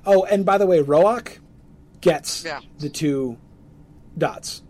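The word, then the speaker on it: Roäc: So the A over the A in Roach has the two dots. – Yes. Yes. So that means it is definitively Roach, two syllables.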